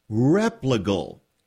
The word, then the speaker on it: Replogle: Replogle. – I would say anything with an R is close enough. I won't be offended. – Replogle.